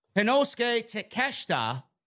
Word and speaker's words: Konosuke Takeshita: John Moxley and Konosuke Takeshita. Did I pronounce that right this time? – Yes, you did.